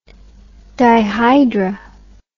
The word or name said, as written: dihydr